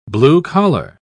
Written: blue-collar

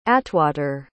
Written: atwater

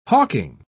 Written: hawking

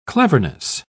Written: cleverness

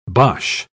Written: bosh

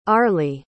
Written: arely

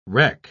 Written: reck

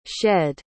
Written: shedd

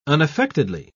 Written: unaffectedly

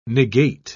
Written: negate

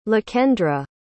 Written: Lakendra

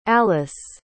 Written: alyce

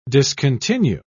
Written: discontinue

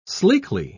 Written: sleekly

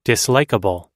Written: dislikeable

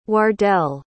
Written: wardell

